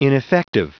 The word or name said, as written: ineffective